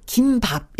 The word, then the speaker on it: 김밥: 우리가 발음을 김밥 이렇게 발음해야 돼요.